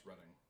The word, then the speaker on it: Reading: I think it's pronounced Reading. – Oh.